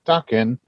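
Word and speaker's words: Daken: Pronounce Dakin. – Dakin.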